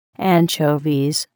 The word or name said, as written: anchovies